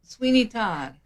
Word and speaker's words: Sweeney Todd: Brainerd Community Theater presents Sweeney Todd. Is that how you pronounce it? – Sweeney Todd.